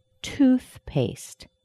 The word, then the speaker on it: toothpaste: Toothpaste. Two words have been put together, tooth and paste. Toothpaste has the primary stress on the first word, tooth, and paste is a secondary stress.